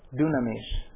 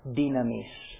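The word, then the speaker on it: Dynamis (δύναμις): In seminary Greek, the word is dunamis. I prefer to use the modern pronunciation in Greek. It seems to go better. It's easier to read it that way. Dynamis.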